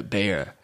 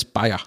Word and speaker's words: Bayer: I think I think, but I think Americans pronounce it uh, Bayer. Yeah, but it's Bayer.